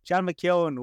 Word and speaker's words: John McKeon: And that it is, I'm going to go with the Irish pronunciation of it. John McKeon, welcome to the Irish NFL show. – That's, I like to hear it. It sounds, my name sounds proper when an Irish person says it.